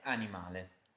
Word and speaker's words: animale: Next, words which end in al become ale in Italian. So can you guess how to say animal? How do you say it? – Animale.